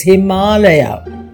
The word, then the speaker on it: Himalaya: Go to YouTube and listen to how we pronounce our Sanskrit word. It's Himalaya.